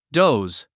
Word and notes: doze: dóuz; ドォーズ

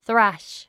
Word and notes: thrash: θrǽʃ; スラァッシュ

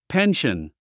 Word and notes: pension: pénʃən; ペンション

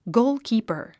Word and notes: goalkeeper: góulkìːpə r; ゴーゥキーパァ

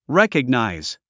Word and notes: recognize: rékəgnàiz; レコグナイズ

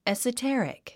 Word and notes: esoteric: èsətérik; エサテェリック

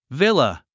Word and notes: villa: vílə; ヴィラ

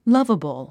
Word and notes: lovable: lʌ́vəbl; ラバブル

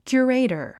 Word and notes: curator: kjuəréitər; キュゥレイタァ

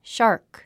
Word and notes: shark: ʃάː r k; シャーク

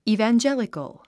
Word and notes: evangelical: ìːvændʒélikəl; イィベンジェリコゥ

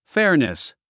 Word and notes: fairness: fɛ́rnəs; フェアネス

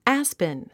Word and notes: aspen: ǽspən; アァスペン

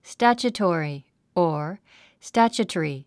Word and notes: statutory: stǽtʃutɔ̀ːri; スタァチュトォリィ

